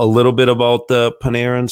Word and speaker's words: Panarin: Um, a Terry, a, a, a what, what, what, how do I say his name? Panarin. – Panarin. Yeah. yeah.